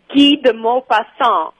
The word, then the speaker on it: Guy de Maupassant: Okay, in French, it's Guy de Maupassant, the writer.